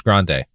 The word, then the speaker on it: Grande: Out with your favorite singer, Ariana Grande. – Sophie, it's pronounced Grande.